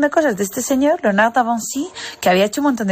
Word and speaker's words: Léonard de Vinci: Pues resulta que me puse a mirar en el libro y era Leonardo da Vinci pronunciado en francés. Yo no me he enterado. Un abrazo de la Farah sin Mar desde Katmandú. – Léonard de Vinci.